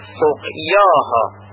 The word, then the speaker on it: سقیاها: سقیاها سقیاها، پس بنابراین یا خودش تلفظ میشه و ناخوانا نیست و پایه الف مدی هم نیست.